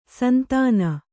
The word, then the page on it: ಸಂತಾನ: santāna